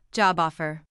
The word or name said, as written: job offer